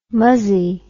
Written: muzzey

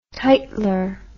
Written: titler

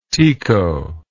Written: tico

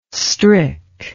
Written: strick

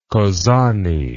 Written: kozani

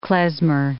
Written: klezmer

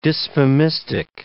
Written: dysphemistic

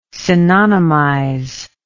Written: synonymize

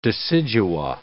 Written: decidua

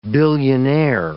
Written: billionaire

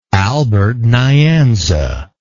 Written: albert nyanza

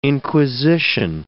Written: inquisition

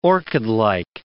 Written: orchidlike

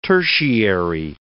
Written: tertiary

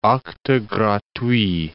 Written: acte gratuit

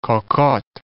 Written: cocotte

